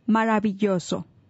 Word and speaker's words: maravilloso: So the word of the day for marvelous in Spanish is maravilloso. – Maravilloso. – Say it again. – Maravilloso. No, you're always laughing at me. – Try to pronounce the R.